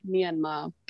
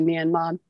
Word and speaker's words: Myanmar: So in Burmese, it's more like Myanmar. But yeah, you hear people, especially like in America, say Myanmar, Myanmar.